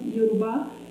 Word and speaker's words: Yoruba: how do you pronounce it? Yoruba, that's why we say in Nigeria, and what we have nowadays in Brazil, in the Afro-American or Afro-Brazilian religions.